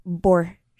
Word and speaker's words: Borchetta: And now we're hearing from Taylor Swift's lawyer saying that Taylor Swift, or excuse me, Scott Borchetta, I think I'm saying that right? – Yeah.